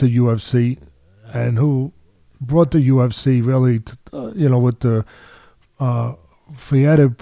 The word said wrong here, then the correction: Fertitta: brothers. I always mispronounce their name. It's okay. They're in line with everyone. Fertitta. There you go.